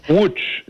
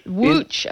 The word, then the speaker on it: Łódź: So you studied film at New York University and the Film Academy in, is it Lodz? How do you pronounce that? L-O-D-Z? – Łódź. – Łódź, okay. – Łódź. Łódź. In Polish and Lodz in German.